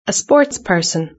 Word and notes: a sportsperson: British English

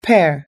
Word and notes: pear: American English

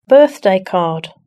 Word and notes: birthday card: British English

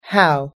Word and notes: how: American English

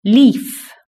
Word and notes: leaf: British English

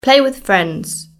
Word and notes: play with friends: British English

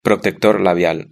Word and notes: protector labial: European Spanish

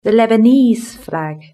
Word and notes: the Lebanese flag: British English

